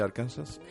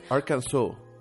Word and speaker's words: Arkansas: ¿Sabes cómo se pronuncia Arkansas? Arkansas.